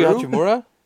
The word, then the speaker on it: Hachimura: Rui Hachimura? – Starts with a...